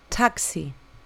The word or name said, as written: taxi